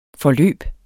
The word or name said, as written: forløb